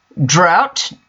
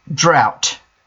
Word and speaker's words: drought: Drought. Note the pronunciation. Drought. Like out. O-U-T.